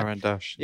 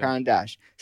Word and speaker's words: Caran d'Ache: I think Caran d'Ache, you pronounce it like that? – Caran d'Ache. – Yeah, yeah. Caran d'Ache.